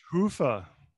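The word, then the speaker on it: Hyvä: Hiva. Is it Hoofa pronounced? – Obviously, you haven't seen episode one of Talk Commerce, where we spend at least 15 minutes pronouncing it. – Really? – It's Hoofa.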